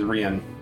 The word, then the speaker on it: Rian: My son's name is Savas, and my husband's name is Rian. – How do you spell that? – R-I-A-N, like Ryan, but it's kind of like, he's fancy.